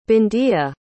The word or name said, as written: bindiya